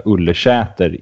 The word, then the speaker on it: Ullersäter: Så att oavsett stavning så kan man väl säga att det ska heta Ullersäter i tal.